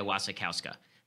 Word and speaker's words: Wasikowska: So if you're into this sort of thing, very good production of Jane Eyre with Michael Fassbender and Mia Wasikowska is how it's pronounced.